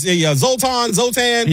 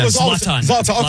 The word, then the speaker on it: Zlatan: Zoltan. – Yeah, I should call him Zoltan. – Zlatan.